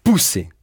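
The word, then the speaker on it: puse: Et au passé, « j'ai mis » se dit « pousser ». Cette fois-ci, la voyelle qui prédomine, c'est un « u hein? ». Prononcé « u ».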